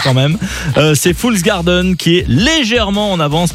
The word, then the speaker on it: Fool's Garden: Fool's Garden.